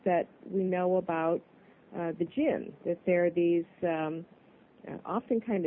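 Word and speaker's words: djinn: So it's jinn. The D is silent.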